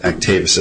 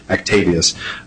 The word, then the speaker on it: Actavis: We briefly touched in the MPAER's brief, which is the reverse payment theory, which is before Your Honor under, I pronounce it, Actavis as well, although Judge Sheridan sort of made it Roman. He would say Actavis or Actavius.